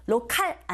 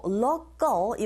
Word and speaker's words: local: 내용어에 힘을 준다라는 준비를 하시고, the words of the local, 로컬 아니고 local 이런 식으로 발음이 되죠.